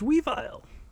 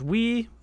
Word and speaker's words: Weavile: And so now we're going to talk about Sneasel's evolution, whose name is Weavile.